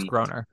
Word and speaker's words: Groener: There was Harry Groener or Grainer. I don't know how to pronounce it. – I think it's Groener.